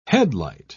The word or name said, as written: headlight